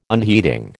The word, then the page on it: unheeding: American English